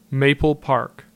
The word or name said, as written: Maple Park